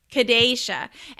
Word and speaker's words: Kadasha: But your name's Kadasha. Is that how you say it? – It's Kadasha. So it's Kadasha. A long A. – Kadasha.